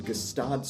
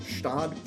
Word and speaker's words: Gstaad: Julie Andrews owns a chalet in Gstaad, Switzerland. – I'm pretty sure you don't pronounce the G in Gstaad.